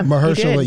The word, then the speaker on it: Mahershala Ali: Mahershala Ali. Did I say his name right? I've been really trying to get this man's name right. – Yeah. – Mahershala.